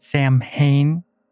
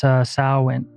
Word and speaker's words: Samhain: A lot of people mispronounce it Samhain because in English that's how it's like sounds phonetically, but it's not pronounced that way. So you've probably heard it before, but it's Samhain and it's Halloween basically.